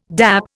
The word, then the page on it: dap: Indian